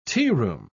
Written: tearoom

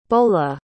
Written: beaulah

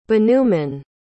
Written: bhanuman

